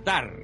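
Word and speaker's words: Tár: Tar, T-A-R, con acento en la A, que también hay que tenerlo en cuenta.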